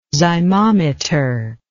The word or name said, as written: zymometer